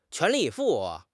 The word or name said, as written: 全力以赴